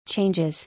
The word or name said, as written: Changes